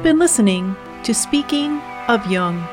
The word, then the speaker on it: Jung: You can also listen to this podcast on your Amazon Echo device simply by saying, Alexa, play Speaking of Jung on Apple Podcasts. Just be sure to pronounce Jung with a hard J.